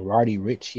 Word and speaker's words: Roddy Ricch: No, I really don't have a real good ear for Roddy Ricch yet. That's how you say his name.